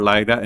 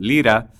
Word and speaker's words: Lyra: Although it is not the AKG Lira or Lyra, and by the way, it's spelled L-Y-R-A. If you speak Italian or Castilian, you might call it Lira, which was the name of the currency used in Italy before the euro came into a while back.